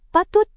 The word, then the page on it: patut: Indonesian